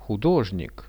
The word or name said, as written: hudozhnik